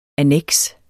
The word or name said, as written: anneks